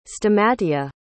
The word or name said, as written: stamatia